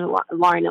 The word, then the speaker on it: Lauryn: My first name is Lauryn Elise, and our names are both spelled L A U R Y N.